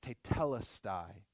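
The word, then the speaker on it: tetelestai (τετέλεσται): And it's a word that's pronounced tetelestai.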